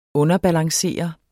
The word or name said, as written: underbalancere